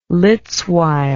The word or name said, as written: litz wire